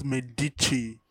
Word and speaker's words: Medici: You don't pronounce it like that. You pronounce it with respect. Pronounce with respect. – Medici. – Are you a fool? It's called, it's pronounced Medici.